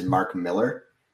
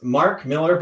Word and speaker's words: Millar: Because you love his comic so much, his name is, even though it's spelled like that, it is Mark Millar.